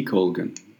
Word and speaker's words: Jenny Colgan: Am I saying the name right? I think. – Yeah, yeah, Jenny Jenny T Colgan.